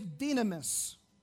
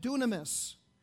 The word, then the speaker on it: dynamis: This Greek word power is dynamis, or you could pronounce it dunamis.